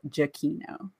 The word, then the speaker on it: Giacchino: We met Michael Giacchino at Disney's at Downtown Disney during D23, and we asked him what his name was pronounced, and he said it was Michael Giacchino.